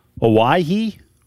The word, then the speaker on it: Owyhee: Owyhee? – Owyhee, yeah.